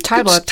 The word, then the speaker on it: Talbot: Talbot.